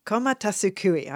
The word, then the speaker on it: komatasukui: Komatasukui.